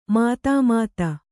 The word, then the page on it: ಮಧುಮತ್ತೆ: madhu matte